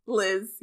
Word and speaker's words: Leezh: L-E-E-Z-H. – Liz, yeah. – Okay, good. – Liz. That's how they pronounce Liz.